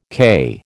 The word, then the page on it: CAI: American English